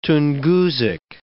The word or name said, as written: Tungusic